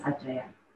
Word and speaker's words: Aceh: Yes, Aceh. – Which is the most west part of Indonesia and have a higher degree of autonomy from a central government. And as a conservative Islam territory, Aceh has the right to create some aspect of an independent legal system implementing Sharia law, for example.